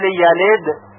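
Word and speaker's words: يلد: توجه داشته باشید که حرف دال در کلماتی مثل یلد و یولد ساکن هست و باید با قلقله تلفظ بشه، یعنی صدای اون جهشی داشته باشه که مستمع بشنوه اون رو.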